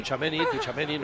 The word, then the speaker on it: Tchouaméni: Oye, ayer con Munilla en el partidazo, ¿cómo llamaste a Tchouaméni? Que me han mandado hoy muchos mensajes, Poli. – Yo, ¿Tsunami o una cosa parecida? – Chuchunami. No, Tchouaméni, no es Tchouaméni.